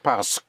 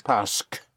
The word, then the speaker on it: parce: The S into the park makes it parce. Parce. – Parce. – Yes. Right. In spelling, it's spelled P-A-R-C-E-Q-U-E in two words. Parce. – Parce. – But in speaking, think of the S in the park.